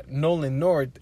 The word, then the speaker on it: Nolan North: Of course, Nolan North is... Did I say that right? Yeah. Nolan North is Nathan Drake.